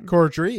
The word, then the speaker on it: Corddry: How do you say his name? – Corddry. Yep. Yeah, you had it.